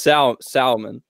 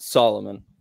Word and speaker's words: Salomon: But now, okay, on the flip side, so, and I heard you, so I've been pronouncing this brand wrong my entire life. It is Salomon, not Solomon.